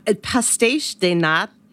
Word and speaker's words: pastéis de nata: One thing I noticed that David would probably like is pastéis de nata. Okay, you taught me how to say this. The Portuguese custard tart. – Let's hear it. – Pastéis de nata.